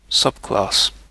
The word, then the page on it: subclass: British English